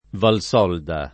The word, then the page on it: Valsolda: Italian